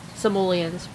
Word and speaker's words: Simoleons: You get 100 grand simoloids. 50 grand simoloids. Simoleons.